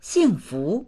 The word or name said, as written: xing4fu2